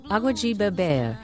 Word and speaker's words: Água de beber: Água de beber, which means water to drink in Portuguese.